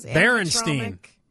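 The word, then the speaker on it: Berenstain: Watch the Bernstein Bears or whatever they are. – Bernstein Bears? – What are those? – Berenstein. Berenstein. – Oh. Well, I've been saying that wrong Bernstein.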